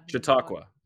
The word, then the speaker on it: Chautauqua: No. C-H-A-U-T-A-U-Q-U-A. How would you pronounce that? – I have Chautauqua. – Chautauqua. Okay, thank you.